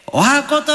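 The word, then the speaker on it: 十八番: 18 番と書いて、お箱と読む。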